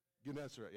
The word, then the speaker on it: Gennesaret: Gennesaret, yeah.